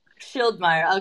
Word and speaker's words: Schildmeyer: Schildmeier.